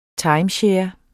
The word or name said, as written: timeshare